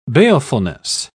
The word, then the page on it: balefulness: British English